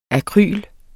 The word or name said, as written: AKRYLET